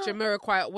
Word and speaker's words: Jamiroquai: Because I always say it gives me like Jamiroquai.